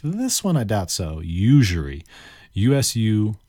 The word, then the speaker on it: usury: All right, let's move on to our next word, which is usury. It's got kind of a weird pronunciation when you look at it. Usury, spelled U-S-U-R-Y. Usury. This one's probably not phonetic.